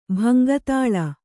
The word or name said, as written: ಭಂಗತಾಳ